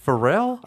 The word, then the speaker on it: Pharrell: Is it Pharrell? – I think that's exactly how you say it.